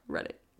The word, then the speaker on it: Reddit: Yeah, read it. Because it's not, like, spelled like Reddit, you know, so it's...